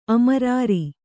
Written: ಅಮರಾರಿ

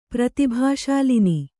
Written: ಪ್ರತಿಭಾಶಾಲಿನಿ

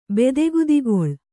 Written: ಬೆದೆಗುದಿಗೊಳ್

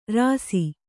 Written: ರುಸಿ

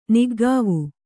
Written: ನಿಕಾವು

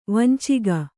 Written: ವಂಚಕಿ

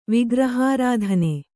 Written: ವಿಗ್ರಹಾರಾಧನೆ

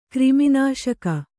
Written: ಕ್ರಿಮಿನಾಶಕ

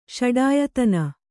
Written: ಷಡಾಯತನ

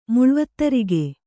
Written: ಮುಳ್ವತ್ತರಿಗೆ

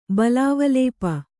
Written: ಬಲಾವಲೇಪ

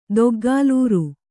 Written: ದೊಗ್ಗಾಲೂರು

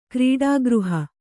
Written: ಕ್ರೀಡಾಗೃಹ

